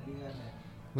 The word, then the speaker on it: Liga MX: Is it Liga MX? Is that how you pronounce it? – Yeah, Liga MX.